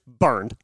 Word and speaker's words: Bernd: It's the B-E-R-N-D is how his first name is spelled. Literally just burned.